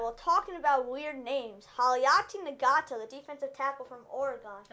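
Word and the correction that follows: Haloti Ngata: And that's not how you pronounce his name, by the way. It's Haloti Nagata.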